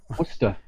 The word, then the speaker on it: Worcester: Worcester. Worcester. Even though it looks like Worcester. – Yeah, it does. W-O-R-C-E-S-T-R. – Yeah, it's pronounced W-O-O-S-T-E-R. Worcester.